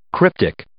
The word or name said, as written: cryptic